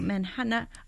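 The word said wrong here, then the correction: Mannahatta: Oh, Manhattan. Manahatta. Sorry, there we go. Manna-hata. I think this is what indigenous people peoples originally called Manhattan.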